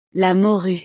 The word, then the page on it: la morue: frances